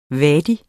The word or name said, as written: wadi